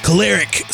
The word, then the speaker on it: Koleric: And this is another, and I'm going to butcher your name, Adriean Koleric, right? – Koleric. – See, look, I already messed it up.